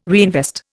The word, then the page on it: reinvest: Indian